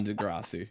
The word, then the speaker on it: Degrassi: Well, as non-Canadians, you know, we couldn't get on Degrassi.